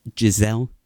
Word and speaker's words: Gisele: Is his wife named Gisele? – Stop it. So no, that's how you say it, right? – That's how you say it. Yes, very good.